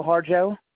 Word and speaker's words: Harjo: Cecil Harjo. – H-O-H-A-R-J-O.